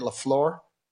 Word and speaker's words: LaFleur: And their coach, LaFleur? Is that how you say it? LaFleur? – Mm-hmm.